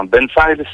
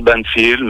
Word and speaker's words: Banfile: Euh, moi, j'ai dit Banfile. Est-ce que je suis dans le juste Banfile, c'est très bien. Banfile, c'est, ou tu peux dire Banfield, mais Banfile passe très bien.